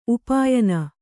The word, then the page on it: ಉಪಾಯನ: upāyana